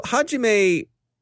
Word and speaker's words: Hajime: And Hajime, I am told by one of the people who works with me here who is who does kendo that it is pronounced Hajime. So Hajime is a bit of an oddity when it comes to IoT malware.